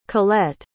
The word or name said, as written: Colette